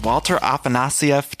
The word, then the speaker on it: Walter Afanasieff: Okay, guys, I'm here with Walter Afanasieff.